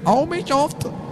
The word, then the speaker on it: Armicraft: Ont été demandés en dommages et intérêts au distributeur local du jeu qui s'appelle Armicraft. Et c'est comme ça que ça se prononce.